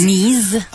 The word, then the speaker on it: Nize: Hello, big happy birthday to, you don't know how to pronounce it though, N-I-Z-E. Is it Nize or Knees? – Knees.